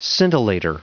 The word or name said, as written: scintillator